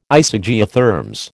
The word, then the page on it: Isogeotherms: American English